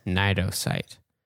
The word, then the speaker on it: Cnidocyte: It is the one that starts with the silent C. – Yeah. Cnidocyte.